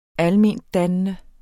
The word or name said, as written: almendannende